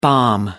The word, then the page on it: balm: American English